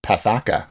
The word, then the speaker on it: PAFACA: Well, yeah, they call it the Protecting Americans from Foreign Adversary Controlled Applications Act, or I guess it's PAFACA, which rhymes with alpaca.